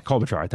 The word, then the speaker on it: Klobuchar: Democratic senators, Amy Klobuchar, I can't say her name right. – Klobuchar, thank you.